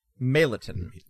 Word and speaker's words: Meliton: Meliton. – Meliton. – M-E-L-I-T-O-N.